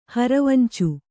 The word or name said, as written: ಹರವಂಚು